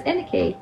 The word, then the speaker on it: Anneke: An- Anik? No, it's Aniki.